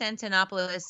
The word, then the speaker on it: Antonopoulos: So, Anopoulos? – Andreas Antonopoulos.